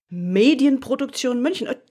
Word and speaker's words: Medienproduktion München: Medienproduktion München. Just say it. You will say it perfect. – No, sure. I won't. I promise you. But I'll try. Medienproduktion. That's the hard part. Medienproduktion München.